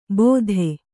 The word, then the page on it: ಬೋಧೆ: bōdhe